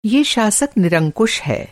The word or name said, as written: शासक